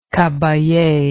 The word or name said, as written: caballe